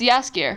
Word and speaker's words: Jaskier: Yaskier is spelled J-A-S-K-I-E-R. – Yeah, it's Yaskier.